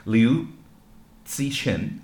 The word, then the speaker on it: Liu Cixin: anyway, I believe his name is pronounced Liu Cixin.